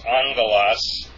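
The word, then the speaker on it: angelos (ἄγγελος): When two consonants come together, they have a kind of an N sound thrown in there. It's angelos. Angelos, that's how you pronounce that.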